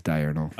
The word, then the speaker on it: diurnal: It is diurnal. – Oh, okay. Diurnal. – Yeah. Diurnal. It's spelled die and then dash urinal. Diurnal.